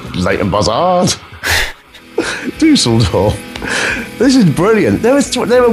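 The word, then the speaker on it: Düsseldorf: The Tips, of course, are from Dusseldorf. – Dusseldorf? – You even say it correctly.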